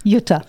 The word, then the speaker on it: Jutta: Jutta.